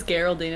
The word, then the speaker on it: Geraldine: Esme and Geraldine. – It's Geraldine. It's a soft G.